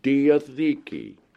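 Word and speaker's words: diatheke (διαθήκη): Now, let's get back to this key word in the New Testament, which should always be translated covenant, which is pronounced diatheke, D-I-A-T-H-E-K-E, diatheke.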